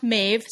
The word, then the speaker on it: Maeve: I've been calling her Mauve like the color and it's it is pronounced Mave.